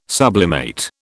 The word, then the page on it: sublimate: British English